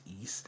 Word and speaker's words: Ys: Or you might see it as, like, YS, and you're like, I'm not sure how to pronounce that. It's East.